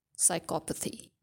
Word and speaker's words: psychopathy: So for sociopaths, you can have two pronunciations. You have sociopathy and you have sociopathy, right? And for psychopaths, the condition is pronounced only one way, and that is psychopathy.